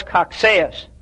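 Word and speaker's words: Cocceius: So we need to investigate a bit Johannes Coxeus. That's C-O-C-C-E-I-U-S, but it's pronounced Coxeus, who lived from 1603 to 1669.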